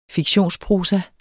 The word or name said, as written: fiktionsprosa